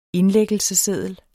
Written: indlæggelsesseddel

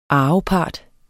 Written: arvepart

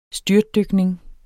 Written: styrtdykning

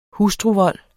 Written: hustruvold

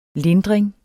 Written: lindring

